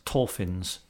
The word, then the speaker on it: Torphins: There was a significant tributary of the River Dee, runs through the village of Torfins, I think it's pronounced, T-O-R-P-H-I-N-S, Torfins.